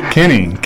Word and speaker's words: Kinney: Kenny. K-I-N-N-E-Y.